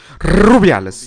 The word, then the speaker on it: Rubiales: Alle spanischen Nationalspielerinnen wollen unter diesem Rubiales. Rubiales. Ich spreche das nochmal mit einem R. Rubiales.